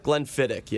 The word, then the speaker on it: Glenfiddich: Glenfiddich, yeah, Glenfiddich.